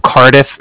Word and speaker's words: Cardiff: Yeah, so it's Nico, N-I-K-O. – Okay. – And then the Bank of Cardiff is spelled C-A-R-D-I-F-F, as in Frank, so it's like car and then different.